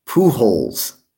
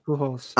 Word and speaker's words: Pujols: Pujols. That's how you say his name. – Pujols.